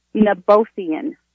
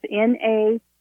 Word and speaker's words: Nabothian: Bolsian? – Nabothian. – How do you spell it? – A cervical. It's Nabothian. It's a cervical cyst type thing.